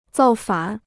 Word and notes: 造反: zào fǎn

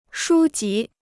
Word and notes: 书籍: shū jí

